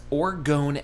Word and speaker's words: orgone: He created a new concept known as orgone energy. – Orgone. Spell that. – O-R-G-O-N-E.